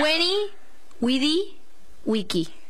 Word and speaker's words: veni vidi vici: Weni Widi Wiki. Y la agua cae. – No, bueno, porque la B corta en realidad se pronuncia como una U en latín.